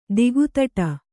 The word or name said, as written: ದಿಕ್ತಟ